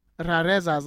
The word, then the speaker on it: Rarezas: I have to roll the R's, right? – Yeah, hold on. I got to look that up so I can actually, like, pronounce it correctly. – Okay. – Yeah, like Retros E. Rarezas, like rares.